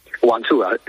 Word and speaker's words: Guangzhou: Sí, como ya he dicho, se dice Guangzhou. Bueno, se dice Guangzhou, but normalmente aquí le damos Guangzhou.